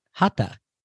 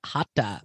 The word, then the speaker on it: Hatha: No, but traditionally, people will not pronounce the H in the second H in Hatha. – But I didn't. I said Hatha.